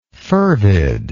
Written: fervidness

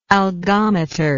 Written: algometry

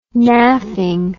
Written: naffing

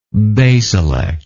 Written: basilect

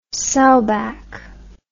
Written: sowback

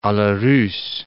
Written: a la russe

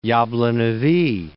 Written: Yablonovyy Mountains

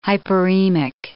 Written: hyperemic

